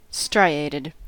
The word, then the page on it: striated: American English